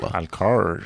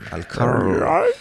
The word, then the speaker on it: Alcor: Pronuncia bien Alcor, para que no suene alcohol. – Alcor. – Alcor.